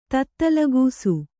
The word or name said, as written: ತತ್ತಲಗೂಸು